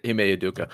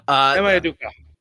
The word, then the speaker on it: Ime Udoka: I really, really like it if they get Wembanyama, but I don't hate this for Umbeko or Unubeko. I don't even know how to pronounce his name. – Ime Udoka. – Ime Udoka.